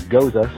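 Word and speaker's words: Gose: I've heard it pronounced a myriad of ways, but to my understanding, the correct pronunciation is Goza, so therefore Goza, the Gozerian.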